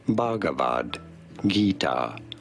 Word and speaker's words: Bhagavad Gita: Bhagavad Gita, often referred to as simply the Gita.